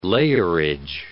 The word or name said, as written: layerage